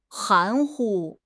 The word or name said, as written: 含糊